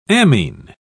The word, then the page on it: ammine: British English